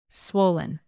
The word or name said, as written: swollen